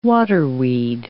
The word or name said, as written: waterweed